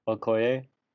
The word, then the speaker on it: Okoye: I don't know how to say this. Okoye? – Yeah.